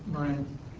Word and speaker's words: Moran: So, So I wanted to introduce two distinct models, what's called the Wright-Fisher model and a Morin model. Is that the right way to say that, Morin? – Moran.